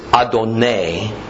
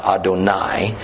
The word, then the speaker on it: Adonai: But biblical Hebrew doesn't give us the vowel sounds. So at times, how to pronounce the word's ambiguous, therefore the exact meaning can be ambiguous. However, here I think the context is self-evident, that the word is Adonai, not Adonai, thus referring to a human Lord and not the divine God.